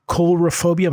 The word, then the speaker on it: coulrophobia: So you guys had uh, with uh, Bernie Mojzes, uh, the other co-editor of Unlikely Stories, a call for um, coulrophobia. Am I saying that right? – that sounds right.